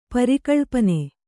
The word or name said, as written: ಪರಿಕಳ್ಪನೆ